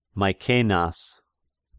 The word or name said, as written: Maecenas